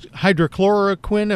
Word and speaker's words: hydroxychloroquine: American College of Cardiologists coming out with a suggestion or a guidance saying that this hydroxychloroquine, am I saying it right? – You are.